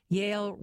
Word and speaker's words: Yale: Yale rhymes with stale.